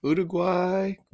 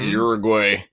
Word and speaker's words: Uruguay: So we were watching Uruguay. We learned how to say it the right way. It's not Uruguay.